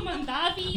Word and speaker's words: Mondavi: It looks like today's episode is sponsored by Matteo Mondari, sommelier summer camp at the Mondari Estate in Napa Valley. Welcome, Matteo Mondari! Am I saying that right? – No, Matteo Mondavi.